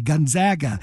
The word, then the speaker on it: Gonzaga: And I have to tell myself every time I say it, okay, short A, Gonzaga. So it's funny you mentioned that, because it still doesn't come fully naturally.